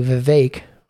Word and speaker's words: Vivek: So in thinking this out and moving it all back around to Vivek rhymes with cake, Vivek Ramaswamy, what I'd like people to do is when you're looking at a candidate for president, they're human beings.